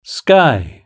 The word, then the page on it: sky: British English